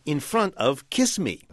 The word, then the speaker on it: chisme: And the group name that he chose for his particular bugs, he spelled C-H-I-S-M-E. – Which, of course, you pronounce kiss me.